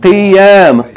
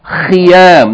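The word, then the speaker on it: Khiyam (خيام): But be careful, don't pronounce it qiyam. Qiyam in salat is standing with qaf. This is with kha. Khiyam. That's why it's important to emphasize the pronunciation of the letter kha. Otherwise, it may be very close to qaf and we get confused with the words.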